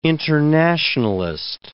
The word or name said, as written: internationalist